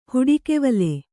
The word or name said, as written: ಹುಡಿಕೆವಲೆ